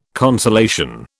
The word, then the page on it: consolation: British English